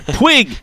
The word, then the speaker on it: Puig: It's P-U-I-G. They used to say the G is silent, but if it was you, if you spelled your name P-U-I-G, I think you would have the U silent.